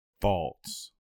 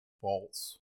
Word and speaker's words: Pfalz: Is that how you pronounce it? – Faults. – Faults. – P-F-A-L-Z.